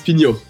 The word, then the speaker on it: Pignon: How did I do on your last name? Like, we didn't go over it, but... – My last name is Pignon. – And yeah, so I said it right. All right. – It's like, yeah, it was perfect.